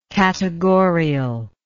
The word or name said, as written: categorial